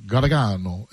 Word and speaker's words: Gargano: It's supposed to be, like, the old world says it, like, Gargano. Gargano is how you say it.